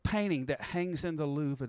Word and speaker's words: Louvre: But it hangs in the, am I saying that right? The Louvre, the Louvre, Louvre, Louvre. Sister French, here, you took French. How do you say it? – You're saying it right. Louvre, Louvre.